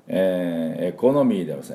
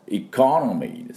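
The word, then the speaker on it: economy: エコノミーではありません。エコノミーです。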